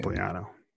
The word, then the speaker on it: Pompliano: How do you say his name? Wrong. It's just Italian. Pompliano. Pompliano.